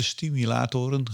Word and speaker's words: stimulatoren: Je bent eigenlijk een van de, nou, ja, een van de grote stimulatoren geweest. Stimulaat, zeg ik dat goed? – Stimulatoren, ja.